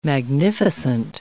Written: magnificent

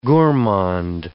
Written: gourmand

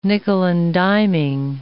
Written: nickeling and diming

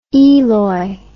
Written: eloi

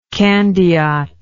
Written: candiot

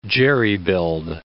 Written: jerry-build

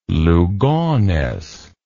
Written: Lugones

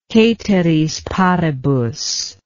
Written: ceteris paribus

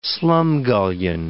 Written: slumgullion

